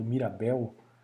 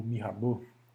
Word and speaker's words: Mirabeau: Que era, é, um biscoito chamado Mirabel. Então muita gente pronuncia por conta de que é um nome muito familiar no, no Brasil, né? Então o Honoré Gabriel, Mirabel, ou Mirabeau, ele era membro da Sociedade dos Amigos dos Negros na França.